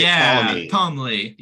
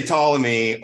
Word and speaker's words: Ptolemy: We just say Ptolemy, or we add a vowel sound between the P and the T if we try to say it like Ptolemy. But like the right, the Ptolemy. But but that's like English more phonological restrictions.